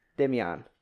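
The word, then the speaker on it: Demián: B-I-C-H-I-R. Bichir. – This is fucking Damien. – D-E-M-I-A, little tick above it, N. – Ah. – Demian. – Demian, that's what that sounds a bit better. – Demian Bichir.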